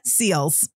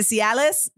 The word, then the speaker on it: Seales: So for the record, my name is pronounced Amanda Seals. Okay? Now, does that stop doctor's offices from always saying Amanda Cialis? No, it doesn't. But listen, they always do it. Amanda Cialis, Amanda Cialis, Amanda Sales.